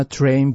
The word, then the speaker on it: Terrain: LTV、これは Lunar Terrain Vehicle、 Terrain、電車の発音に似ていますが、スペルは TERR、 2 つある、その後に AIN、 TERRAIN、これ地形とか地勢という意味です。まさに月面車両ですね。ゴム製のタイヤに代わって月面車両 LTV というタイヤを使って The lunar terrain vehicles, LTVs, need special wheels to move on the moon's rough surface.